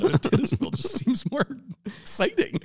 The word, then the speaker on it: Titusville: Then, in late February, he was ready to try again, and this time made it as far as Titusville, Florida, setting a new record. – It's probably Titusville. – I like to pronounce it Titusville. – Okay. Well, you were wrong, but that's fine.